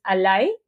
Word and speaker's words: ally: Em, y también que Ali, o sea, así se escribe, al, o sea, en realidad en inglés es ally, ¿no? Que significa aliado y se escribe con, como tal cual, A-L-L-Y.